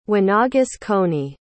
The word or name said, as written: Winaugusconey